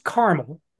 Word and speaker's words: Carmel: It's pronounced Carmel, like the biblical Carmel. Apparently, there are a lot of cities in the United States called Carmel, and they're all pronounced Carmel except the famous one in California, which is Carmel by the Sea.